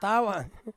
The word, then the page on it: tawan: Ansus